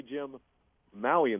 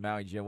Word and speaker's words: Maui Jim: And so, remember, the Maui Gym, Maui Invitational. – Right. – little pause. – See, I even said it wrong. I think yeah. I said the Gym Maui. It's the Maui Gym, whatever it is.